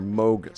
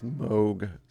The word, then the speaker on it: Moog: But we're not playing Dionne Warwick, we're playing Christopher Scott performing on the Moog synthesizer, or Moog synthesizer, I should say. It's Moog synthesizer is the proper pronunciation, but in the 70s, everyone called it the Moog synthesizer because it's M-O-O-G, and hence the plastic cow goes Moog.